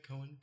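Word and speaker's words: Coen: Am I saying it right? – Cohen.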